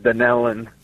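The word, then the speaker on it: Dunnellon: I'm going to go with Dunalon, Florida, watching on YouTube. I hope I said the town right. I have not heard of that town before. – No, actually, it's pronounced Dunellen.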